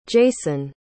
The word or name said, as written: jayson